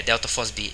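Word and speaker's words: delta FOSB (ΔFosB): Aliás, é, delta FOSB é que se, é, se escreve IFOSB, mas pronuncias delta FOSB.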